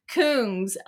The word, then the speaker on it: Kungs: The song is a collaboration between French house artist King. Oh, it's pronounced Kungs of Melbourne, Australia funk trio Cooking in Three Burners.